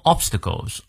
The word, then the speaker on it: obstacles: by 双元音念到位，prevailing prevail 重音在后，by prevailing over all obstacles obstacles 重音在前，and distractions distractions 重音在 ch 这个音，one may unfailingly unfailingly 中在第二个音节，fail unfailingly arrive at his chosen，注意它的元音 goal or destination。